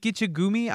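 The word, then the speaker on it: Gichigami: Lake Gichigumi? I'm saying it wrong. – That's right. – Gichigumi is what the natives called Superior.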